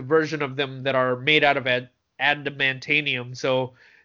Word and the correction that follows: adamantium: adamantium? – Adamantium, sorry. So adamantium. Yeah.